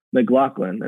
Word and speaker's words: McGlocklin: And I've never butchered it once, but McGlocklin, and couldn't do that.